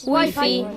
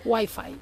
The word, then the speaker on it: Wi-Fi: Wi-Fi. – Molt bé, hi ha hagut pronunciació espanyola i anglesa, Molt bé. – Wi-Fi.